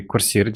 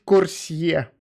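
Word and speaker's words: Coursier: Э, это тот чувак, который курсир делает, вот. – Правильно произносить курсье. Почему не курсир? Это французское слово.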